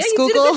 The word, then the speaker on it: Schuylkill: We're right next to gorgeous rail trails along the Schuylkill. Is that how you say it? – Schuylkill. Yeah, Schuylkill. – You did a good job.